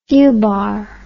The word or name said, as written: fubar